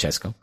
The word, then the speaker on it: CSKA: 3-0 against CSKA Moscow, CSKA Moscow, which is pronounced Chesco.